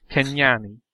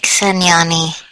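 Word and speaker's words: Cenyani: we move along to our featured conlang today, which do not pay attention to the spelling. It is pronounced Kenyani. it looks like Senyani.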